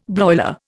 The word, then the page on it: broiler: Indian